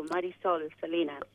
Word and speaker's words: Marisol Salinas: No, you did well, Marisol Salinas.